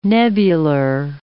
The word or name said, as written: nebular